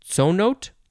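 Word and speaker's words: ts'onot: This is a Mexican-Spanish word from the Yucatec word, how would you pronounce this? Tsonote? It is T-S apostrophe O-N-O-T, tsonote. That's what I'm going to guess.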